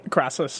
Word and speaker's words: Crassus: Crassus.